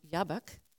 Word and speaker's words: Jabbok: So Jabbok, pronounced Yabbok, wayback, Yabbok, is like a pun on words.